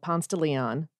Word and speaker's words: Ponce de Leon: Here in Atlanta, we have a road called Ponce de Leon, and that's how you say it.